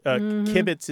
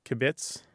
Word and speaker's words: kibitz: And so, and is it kibitz or kibitz? – It's both. Kibitz is the more traditional pronunciation, but kibitz is also used.